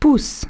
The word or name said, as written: poussent